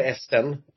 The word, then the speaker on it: S10: Det är S10 kallar sig artisten för och eh, uttalat då på engelska S10 eller Esten.